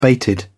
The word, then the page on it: bated: British English